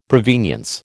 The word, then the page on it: provenience: American English